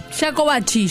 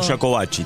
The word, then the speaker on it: Jacobacci: Tony de, ¿cómo se dice? ¿Jacobacci o Jacobacci? – Jacobacci, yo digo Jacobacci también, Jacobacci, por eso.